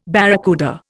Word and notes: barracuda: Indian

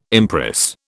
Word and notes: empress: British English

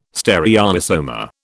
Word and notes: stereoisomer: British English

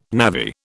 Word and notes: navvy: British English

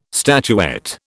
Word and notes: statuette: British English

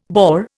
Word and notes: boar: Indian

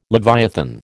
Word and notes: leviathan: American English

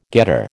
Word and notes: getter: American English

